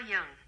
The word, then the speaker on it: Jung: Carl Jung.